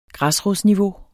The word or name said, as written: græsrodsniveau